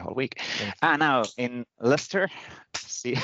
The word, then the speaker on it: Leicester: Now, then we have this SharePoint Saturday from Leicester. – We're having a chat on Leicester. Why do you add letters on the words if you don't pronounce them?